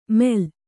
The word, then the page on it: ಮೇಲ್: Kannada